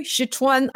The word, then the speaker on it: Sichuan: Sichuan.